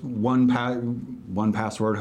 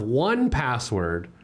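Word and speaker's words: 1Password: LastPass, Dashlane, first one password, however you want to pronounce that. One the number password.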